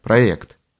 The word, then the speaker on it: проект: Как известно, некоторые не самые образованные носители русского языка слово «проект» произносят не так, как надо «проект», а с «е», просто потому, что так пишется.